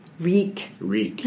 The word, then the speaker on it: Reek: Reek. – Yeah. Rhymes with leak.